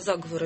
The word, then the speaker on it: заговоры: Да, заговоры. – Заговоры. Правильно вы ударение куда я поставила? – Да, заговоры.